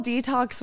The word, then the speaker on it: DeTalks: And So it's called Detox with Allison, and it's spelled DETALKS. So like the word talking, but to say it like detox,